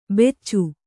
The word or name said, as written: ಬೆಚ್ಚು